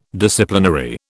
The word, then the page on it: Disciplinary: British English